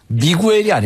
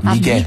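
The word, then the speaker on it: Miguel: 이게 가수가 미구엘이 아닙니다. 오. 스페인어 발음으로는 오케이. 미겔입니다. 미겔. – 아, 미겔. 네, 미겔이라고 하는 게 훨씬 더 정확한 발음입니다.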